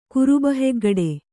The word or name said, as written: ಕುರುಬಹೆಗ್ಗಡೆ